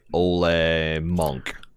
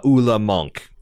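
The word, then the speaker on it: Ole Munch: His name is intended to be pronounced like Ole Monk. – Yeah. – Ula Monk. But like on the show, it definitely seems Old Bunch is what they're rolling with.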